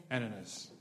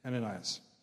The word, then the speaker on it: Ananias: Ananias. – Thank you. Ananias.